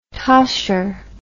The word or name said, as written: tosher